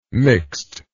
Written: mixt

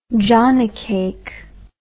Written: jonnycake

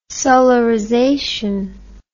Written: solarization

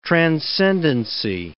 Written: transcendency

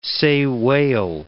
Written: sei whale

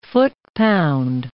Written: foot-pound

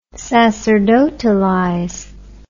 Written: sacerdotalize

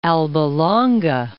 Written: Alba Longa

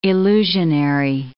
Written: illusionary

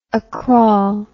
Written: acrawl

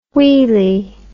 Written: wheely

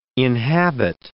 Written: inhabit

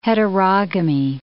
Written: heterogamy

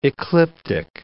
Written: ecliptic

